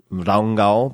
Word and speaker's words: Ranga: And we stayed at this place called Hotel, I think they pronounce it Rangal, but it's Ranga, R-A-N-G-A, which is in the middle of nowhere.